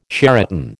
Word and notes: Sheraton: American English

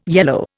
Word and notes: yellow: Indian

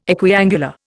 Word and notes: equiangular: Indian

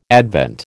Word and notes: Advent: American English

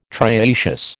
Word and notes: trioecious: American English

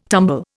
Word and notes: tumble: Indian

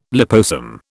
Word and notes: liposome: British English

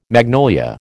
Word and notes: magnolia: American English

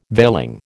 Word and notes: veiling: American English

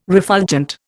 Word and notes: refulgent: Indian

